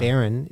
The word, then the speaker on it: Barron: Barron is the pronunciation.